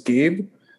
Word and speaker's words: Gabe: My name is Gabe. That's spelled G-A-B-E.